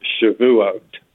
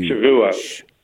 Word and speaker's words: Shavuot: So what you're referring to, the giving of the law on Mount Sinai, is pronounced Shavuot. Shavuot. – Thank you. – Shavuot.